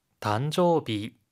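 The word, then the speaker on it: 단조비 (誕生日): 단조비. 단조비. – 탄생일인데요. 단조비. 이때 일이 비로 발음이 되죠.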